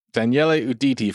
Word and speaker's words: Daniele Uditi: Daniel Uditi. Did I pronounce that right? – Daniele Uditi from Pizzana here in LA, who's the head judge of Best in Dough.